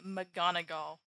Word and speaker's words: McGonagall: And then he goes up to McGoggle, whose name I have been mispronouncing. It's actually most likely McGonagall, though with an I and not an A.